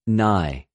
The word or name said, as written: ναι